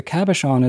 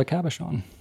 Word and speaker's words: cabochon: Cabochon.